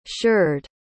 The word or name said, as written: sheard